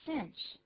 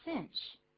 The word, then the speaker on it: cinch: It's a cinch. It's a cinch. – It's a cinch. 或者 it's a piece of cake. 但那个 cinch 那个发音还不太好念，不要念 cinch 啊，怎么念的呢？Cinch. Cinch. 注意这个 i 的发音，一样是写成 i。 i 有时候念作 e，有时候念作 i。